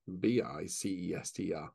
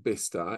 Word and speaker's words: Bicester: simply pronounced as Bicester, Bicester. And it's that typically English thing, isn't it? We have sometimes the oddest pronunciations of things.